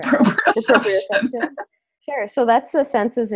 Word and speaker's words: proprioception: then, so this is, I'm not going to say it right because I've never tried to say it out loud before. I've only read it. Proprioception. – Yeah, okay, that's right.